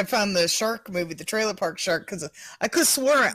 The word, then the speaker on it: Trailer Park Shark: Trailer Park Shark.